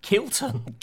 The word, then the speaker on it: Kilton: Kilton. With a K.